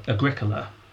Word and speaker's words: Agricola: Because Agricola, or Agricola, if you pronounce it incorrectly, but more fun, has an entire history written about him.